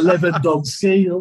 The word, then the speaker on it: Lewandowski: So Lewandowski, that guy.